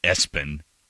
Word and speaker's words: ESPN: And, of course, we all heard about the guy that named his kid ESPN and pronounced it Espen.